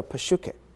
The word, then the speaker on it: psyche: 当然在希腊文里面，我们这边所用的所写的所有的所谓血气这个字，它的希腊文叫做 psyche， P-S-Y-C-H-E，那么英文的念法呢，通常 P 是不发音的，所以就变成像 psychology， psychist 等等，就 P-S-Y-C-H 这个字的字头，你去查字典的话，这一排的字很多的，都是跟心理学啦什么这一类有关的。